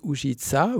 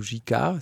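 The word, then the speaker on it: Ujică: Et donc, ça sera aussi évoqué à travers un autre film qui s'appelle Out of the Present de Andrei Ujica, ou Jika, si on ne veut pas le prononcer, à la manière roumaine,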